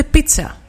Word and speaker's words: πίτσα: Και πώς η πίτσα, λέει, αυτόν ήθελα 10, προφέρεται πίτσα. Σύμφωνα με την Νίνα από το Γουσού που έχει τελειώσει τελική φιλολογία, κάποιες λέξεις όπως η πίτσα που έχει με δύο ζήτα προφέρεται με τσου, κάποιες με τζου.